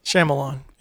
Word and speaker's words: Shyamalan: Shyamalan. M. Night Shyamalan. – Shyamalan.